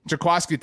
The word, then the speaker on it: Jaquiski: If Jaquiski Tart, I'm pronouncing this correctly, right? – Jaquiski, thank you very much.